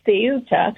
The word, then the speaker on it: Ceuta: And the visible video of the swarms of people coming through the water onto the shore of a town called Ceuta, it's pronounced in Spanish with a T-H, Ceuta.